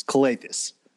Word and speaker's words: Calathes: I'm going to come at you for the pronunciation of Nick Calathes. It's Calathes. Don't need to stress the E there. Nick Calathes.